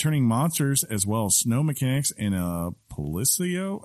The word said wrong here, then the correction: Palico: How do you say that, Sean? – Palico.